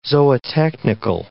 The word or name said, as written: zootechnical